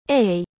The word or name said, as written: ei